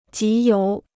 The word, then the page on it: 集邮: jí yóu